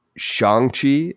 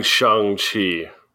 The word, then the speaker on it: Shang-Chi: This week, we are covering Shang-Chi. Is that did I say it right for the first time? – Yes, we're recovering covering Shang-Chi.